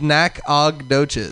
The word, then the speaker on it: Nacogdoches: Nacogdoche. – Dude. Then you want to see where I'm from. We're going to look it up. – Nacogdoches? Is that how it's spelled? – It's Nacogdoches.